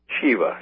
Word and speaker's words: Shiva: It is also intriguing to note that the god Shiva, which is a synonym for Satan, Shiva, that's how you say it.